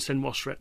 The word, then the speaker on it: Senwosret: You correct my pronunciation. Amenemhat and Senwosret. – Yeah, Amenemhat the first and Senwosret the first, yeah. – There you go.